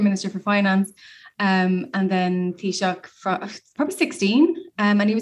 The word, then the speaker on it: Taoiseach: I'm going to try and pronounce this. Taoiseach. – It's a Taoiseach. – Taoiseach. – Taoiseach. That's right. That's how to say it, isn't it? – Yeah.